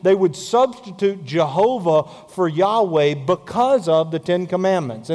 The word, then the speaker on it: Yahweh: And we know from that that the word would be pronounced Yahweh.